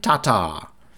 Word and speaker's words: Tatar: On the women's side, not a surprise, number one in the world, Kristin Tatar. Tatar. There's, I think you emphasize the first syllable more, so it's Tata.